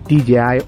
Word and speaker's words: DJI: Y esa es la pronunciación correcta. DJI Osmo Action.